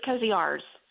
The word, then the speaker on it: Koziarz: It's Koziarz. – Oh, it is. Okay. – Yes, I'm sorry. – oh, okay. Rosie said it's the, the second Z is silent, so uh, we just miscommunicated a little bit.